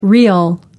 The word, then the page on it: rial: American English